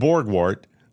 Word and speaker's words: Borgwardt: Ryan Borgwart, I'm pronouncing it correctly, B-O-R-G-W-A-R-D-T, Borgwart.